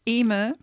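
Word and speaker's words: Eimear: Emir. – Yep. Welcome to the program. And I'm having a little bit of difficulty, dear listeners, with Emir's name because being Irish, it's spelt differently to how it's pronounced.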